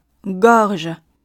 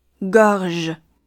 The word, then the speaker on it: gorge: 第二个词 “gorge”，还有第三个词的 “donner” 都共享一个大口 “o” 的发音。发音要领是把嘴自然的张开，呈现一个大口的圆形 “o”。 gorge。值得注意的是，第二个单词最后的 “r”。类似于中文中的日，要区别于英语中的之的发音。 gorge，gorge。